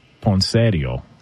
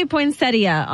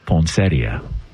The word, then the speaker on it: poinsettia: Well, the in-laws would call it poinsettio. – Oh, gosh. – Okay. What up? You know what I'm saying? – Oh, God. – Can we get the proper pronunciation on this? – It does say poinsettia online.